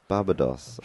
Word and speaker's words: Barbados: Barbados. Are we sure that it's not technically pronounced Barbados? – Pretty sure. – Barbados.